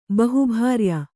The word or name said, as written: ಬಹುಭಾರ್ಯ